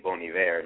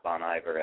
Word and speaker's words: Bon Iver: Yes, I stand corrected with the pronunciation of his name, Bon Iver, not Bon Iver, as many of us ignorant folks call it.